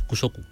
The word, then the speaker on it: Kusoku: Kusoku. Además, se pronuncia es muy fácil. Esto no hay, Kusoku, ¿no? Mala idea, ¿no? – No. En este caso no. Aunque parece que por la K puede ser... No, es Kusoku, como suena, es leve.